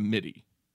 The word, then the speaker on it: MIDI: So I had like uh like I feel like half a sixteen stone in meaty form. So you go meaty instead of midi.